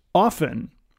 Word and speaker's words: often: and I found out that it can be pronounced both ways, and that is often.